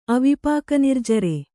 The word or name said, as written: ಅವಿಪಾಕನಿರ್ಜರೆ